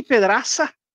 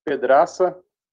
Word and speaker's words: Pedraza: É, Pedraça é a pronúncia correta de um sobrenome de antepassados meus é, proveniente da Espanha, portanto, o Z tem um som de cedilha, por isso que se fala pedraça na língua espanhola.